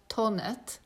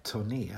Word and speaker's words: Thonet: Och de flesta, ja, det ska uttalas Tonet. – Och jag har alltid sagt turné. – Ja, jag har också sagt turné.